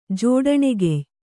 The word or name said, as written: ಜೋಡಣೆಗೆಯ್